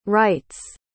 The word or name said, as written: rights